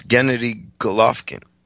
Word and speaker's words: Gennady Golovkin: No, it's Gennady Golovkin.